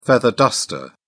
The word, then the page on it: feather duster: British English